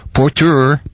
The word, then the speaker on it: Porteur: The porters, seeking opportunity to carry luggage for the tips, yelled out, "Porteur, porteur, porteur," with accent on the last syllable, or equally on both.